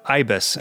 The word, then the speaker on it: Ibis: The tune Ibis. Am I pronouncing that right? Yeah, I think so. It's a type of bird, and I think that's how you say it.